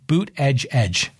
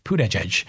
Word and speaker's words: Buttigieg: Pete Buttigieg. That's how you say it, like a boot, like a cowboy boot, and then edge. Buttigieg.